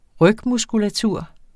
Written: rygmuskulatur

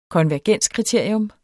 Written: konvergenskriterium

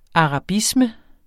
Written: arabisme

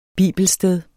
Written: bibelsted

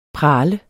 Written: prale

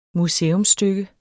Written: museumsstykke

